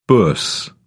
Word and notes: bourse: British English

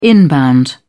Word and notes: inbound: British English

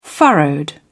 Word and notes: furrowed: British English

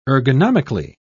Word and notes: ergonomically: British English

